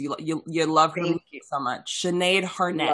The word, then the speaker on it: Sinead Harnett: Sinead Hart. – Harnett? Sinead Harnett? – Yes. Harnett? Oh, I said Hart. – Net? – I even read it wrong.